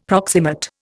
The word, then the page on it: proximate: Indian